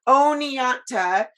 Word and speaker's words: Oneonta: Is it Oneonta? How do you say Oneonta. – Oneonta.